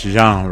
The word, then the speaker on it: Jean: Also, new stuff from Wyclef Jean, or Jean. I like to pronounce it French, Jean, right? Isn't that right? – Yeah. – Because he's Haitian.